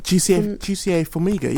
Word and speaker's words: Jussier Formiga: Am I saying his name right? – Jussier Formiga, yeah.